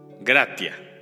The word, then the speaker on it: gratia: La palabra gracias proviene del latín y lo voy a decir tal cual como se escribe. Gratia.